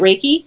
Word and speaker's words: Reiki: Is it Reiki? – Reiki. Yeah. Like ray from the sun and ki you put in your car.